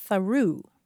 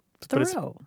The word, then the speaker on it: Thoreau: She said, I taught at an Indian reservation in New Mexico, and the author named Thoreau is a town name there, but it's pronounced Tharu. – Tharu. – Tharu. So you go through Tharu. But you'd think it would be pronounced Thoreau.